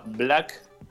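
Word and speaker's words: 6lack: Eh, otro rapero que se llama Black, pero en realidad es Six Black, o sea, en vez de la B larga, el 6, y después como suena el resto de la palabra Black como se escribe, digo, la palabra Black, y Benji, que es eh, la última, la última incorporación eh, del grupo.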